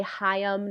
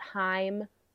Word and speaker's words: Haim: So her, I would say Aurora, I would say Haim, which I just found out is two syllables. They say Haim, not Haim.